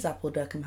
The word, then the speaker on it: Appuldurcombe: Well, it is Appledurcum House, but it's not spelled like apple, like an apple. It's A-P-P-U-L.